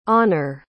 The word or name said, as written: onur